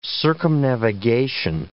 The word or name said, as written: circumnavigation